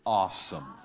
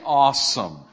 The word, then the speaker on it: awesome: Say it correctly. Awesome. How do you get an O out of that? All right? There's an A there, okay? Say awesome. Awesome. Awesome.